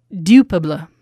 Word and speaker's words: Dupuis: I used to even, I mean, this, I've been around this wine for years and I never can pronounce it. Dupuis. – Oh, that was perfect.